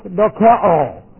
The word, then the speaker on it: dokeo: The Greek word is dokeo. It's like this. In the Greek, D-O-K-E long O, dokeo, is the Greek word.